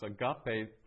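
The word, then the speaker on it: agape (ἀγάπη): It's agape. The accent's on the second syllable, so agape.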